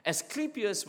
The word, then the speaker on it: Asclepius: And who is, Asclepius, let me pronounce that correctly. Asclepius was the serpent god.